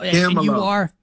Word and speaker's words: Camelo: Camelo. Say it like this. Hold on. Do it with me. Ready? Camelo, baby. There you go, Camelo, baby.